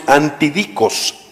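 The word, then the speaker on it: antidicos (ἀντίδικος): Ok, la palabra adversario es una palabra griega que se pronuncia más o menos antidicos, antidicos. Y esa palabra antidicos es una palabra jurídica que identifica lo siguiente.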